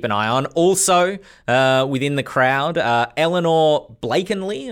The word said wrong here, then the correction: Blakeney: am I pronouncing that correct? – Blakeney.